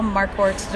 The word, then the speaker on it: Marquardt: My name is Michelle Marquardt DeVoe. I say it's like a mark on the board and a wart on your finger.